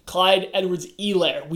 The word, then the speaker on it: Edwards-Helaire: Sorry, Clyde Edwards-Helaire. We were asked to pronounce his name correctly when we did this. Clyde Edwards-Helaire. And he looked great.